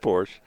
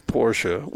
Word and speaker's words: Porsche: Someone who's I can't remember who it was, but they said that they were requesting if they could borrow my Porsche because their Bugatti was in the shop. – It's pronounced Porsche. – It's Porsche. We asked the freaking Porsche dealer, and he said Porsche.